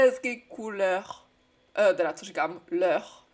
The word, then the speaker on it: leur: Tady to EU má dva typy výslovnosti ve francouzštině. Jednou je to jako němý Ečko, říkáš jako E. Jednou je to jako takový Ečko, otevřený s našpulenou pusou. Není to jako E, ale E. Leur. Vidíš tu polohu pusy jinou? Je strašně důležité to rozlišovat.